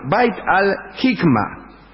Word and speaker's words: Bait al-Hikma: Como las traducciones y los originales empezaban a amontonarse en el Palacio Dorado, Al-Mamun ordenó construir un lugar que se llamó Bait al-Himka. Lo dije mal. Bait al-Hikma. Bait al-Hikma.